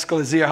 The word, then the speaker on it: Ecclesia: Birthing of the ecclesia. Yes. Ecclesia, however you pronounce it. You pronounce it different. Ecclesia, there you go.